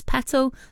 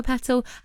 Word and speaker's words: Petal: We picked them ourselves and mine was Petal, like a flower petal. However, with the American accent, I can, well, you can imagine that they don't pronounce their T's. So I was Petal, which sounds like bike pedal.